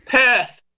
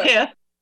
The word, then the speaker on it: Perth: Perth. Not Perth. It's like purse, but with a lisp.